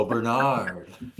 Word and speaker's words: Bernard: Oh, Bernard. The long A's in English.